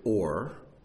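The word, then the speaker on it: Or (אור): But the word for light in Scripture is Aleph, Vav, Resh, and it's pronounced Or, O-R.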